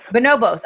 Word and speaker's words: Bonobos: Bonobos.